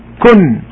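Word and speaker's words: Kun (كن): Okay, so there are three letters, K, U, and N here, okay, for us. But it's really two. Kun, but look at pronunciation, kun, it appears as if there are three letters, but it is K and N, kun, tay, in Arabic.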